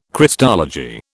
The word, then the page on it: Christology: British English